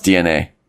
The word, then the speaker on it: DeNA: Oh, wait. Apparently it's pronounced DNA.